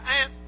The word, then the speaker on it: aunt: They say if your aunt, as some people said, your aunt, A-U-N-T, spelt A-U-N-T, pronounced A-N-T, like an ant, little bug.